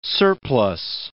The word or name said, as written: surplus